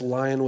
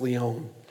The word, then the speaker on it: Lyon: And some of you may read that and say, like, that's lion with a Y, but it's pronounced Lyon.